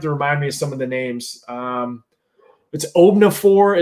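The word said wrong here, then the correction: Obanor: Is that correct? – From O'Banner. Kevin O'Banner from Oral Roberts. That's how you pronounce it? It's O'Banner? – Yep. – Okay, it doesn't look spelled that way.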